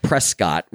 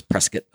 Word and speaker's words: Prescott: Somebody mispronounced Prescott. They said Prescott, which is how it's spelled, but everybody out there says Prescott.